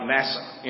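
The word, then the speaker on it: nasa (נשא): Like NASA, N-A-S-A, but it's pronounced nasa.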